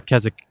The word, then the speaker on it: Keswick: Keswick, it's spelled K-E-S-W-I-C-K. The W is silent.